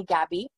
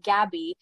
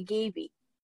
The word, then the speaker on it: Gaby: But what's unique about my name, Gabby, is phonetically, Gabby is G-A-B-B-Y. But, you know, I'm kind of unique. So my name is spelled with one B, not two. So it's G-A-B-Y. Yeah. Now, phonetically, that would be Gaby. Mm-hmm. So because of that, if someone has read my name, they often call me Gaby, mm-hmm. Which is not my name.